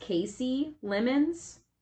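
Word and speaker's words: Kasi Lemmons: So actually, speaking of Silence of the Lambs, of all things, I believe it's pronounced Casey Lemons.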